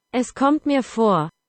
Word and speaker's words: Es kommt mir vor: In German, according to Google Translate, it's pronounced Es kommt mir vor.